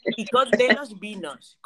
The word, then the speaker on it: Icod de los Vinos: Icod, ¿cómo, cómo? ¿Pero? ¿Cómo se escribe? Icod de los vinos, I-C-O-D de los vinos, vino. – Ah, de los vinos.